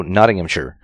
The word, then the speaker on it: Nottinghamshire: I actually was told that Shire is not pronounced Shire unless it's pronounced Sheer or something like that. – So Nottinghamshire.